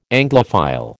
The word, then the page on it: Anglophile: American English